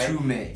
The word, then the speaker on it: Mtume: Yeah, so it's a group called Mtume. Mtume, I think that's how you pronounce it, right? – Mtume. – Yeah, Mtume. – Yeah. – So it's spelled M-T-U-M-E. – M-T-U-M-E.